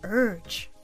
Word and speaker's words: urge: 例えば、私は強い衝動に駆られたという時は I felt a strong urge 今日はこの urge を言ってみましょう。 Repeat. Urge. Urge. 発音のポイントは口を「い」の形にして「う」と発音そこに「 r」の音をつなげる意識でやってみましょう